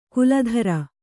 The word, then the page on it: ಕುಲಧರ: kuladhara